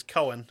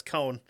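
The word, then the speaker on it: Koehn: I mispronounced it as Cohen. I'm like, no, that's Cohn.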